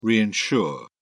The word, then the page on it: reinsure: British English